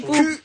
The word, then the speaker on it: Quipu: Ça s'écrit Kipu. Q-U-I-P-U.